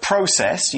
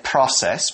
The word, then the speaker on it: process: The word process, you can also say it process, both are fine, means the way in which something is done. Okay, it's spelled P-R-O-C-E-S-S, process or process.